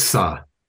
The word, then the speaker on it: ça: I think it's sa. When it's a C with a little thing underneath, it's kind of pronounced like a S.